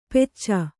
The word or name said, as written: ಪೆಚ್ಚ